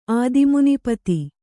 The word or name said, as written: ಆದಿಮುನಿಪತಿ